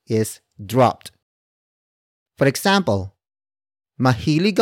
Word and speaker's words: mahilig: Mahilig, akong, mamili, ng, sitsirya. Mahilig, akong, mamili, ng, sitsirya. The first word is mahilig. Mahilig. Mahilig. Mahilig is spelled as M-A-H-I-L-I-G.